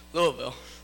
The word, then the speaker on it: Louisville: Or Louisville, how the locals say, Louisville.